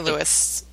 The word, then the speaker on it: Louis: And I don't know if it's actually pronounced St. Louis or St. Louis. – We say Louis.